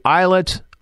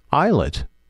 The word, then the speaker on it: islet: So let's take a listen to some Lapse from last year's EP, Islet. Islet. Mm-hmm. Same word, different meaning. Same pronunciation, different meaning.